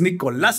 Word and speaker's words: Nicolás: Pero no vaya el acento, cabrón, va en la A es Nicolás.